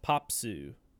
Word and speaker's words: Pape: well, it's P-A-P-E, so I was like Pappy or Pape. – Yeah. – It's pronounced Popsu.